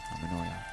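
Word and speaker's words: harmonoia: From harmony and paranoia. Pronounced harmonia.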